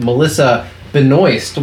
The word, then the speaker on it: Melissa Benoist: Let it be known that we did talk about Melissa Benoist, which that is how you pronounce her name.